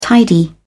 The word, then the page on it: tidy: British English